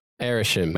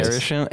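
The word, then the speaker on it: Arishem: Are you sure it's Asherin? – It's not how you pronounce it. – That's not how it's pronounced. – Arishim. – Anyway, Arishim. Arishim, yeah.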